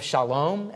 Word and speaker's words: Shalom: He's the king of Salem, or the king of Shalom, as we would pronounce it in Hebrew, meaning the king of peace.